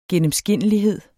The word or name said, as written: gennemskinnelighed